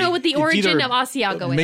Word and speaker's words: Asiago: But look, what is Asiago? – That is, okay.